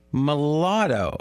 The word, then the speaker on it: mulatto: But he's a mulatto. Is that how you say it? Yes.